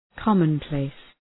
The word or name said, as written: commonplace